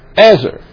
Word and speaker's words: ezer: Here is the Hebrew word. It's E-Z-E-R. Ezer. And here it is in the Hebrew. And it's pronounced Ezer.